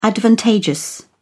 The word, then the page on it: advantageous: British English